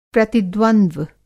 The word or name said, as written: प्रतिद्वंद्व